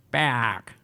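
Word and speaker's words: Peák: dus ik heb gevraagd, ja, hoe, hoe, spreek je die naam eigenlijk uit? En die hebben dus tot in den treuren dat weekend alleen maar tegen me... Paak.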